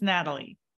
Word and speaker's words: Nathalie: No, the H is silent. – It is silent. So it's Natalie.